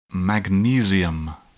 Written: magnesium

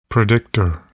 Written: predictor

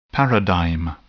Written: paradigm

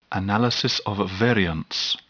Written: analysis of variance

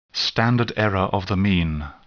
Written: standard error of the mean

